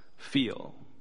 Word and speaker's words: FHHL: It is for young adults, and it is titled FHHL. And we are going to pronounce that as feel.